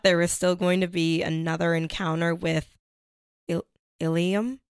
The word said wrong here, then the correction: Ilima: Ilima? – Ilima? Sorry.